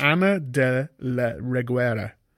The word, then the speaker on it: Ana de la Reguera: Ana de la Reguera.